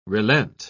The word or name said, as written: relent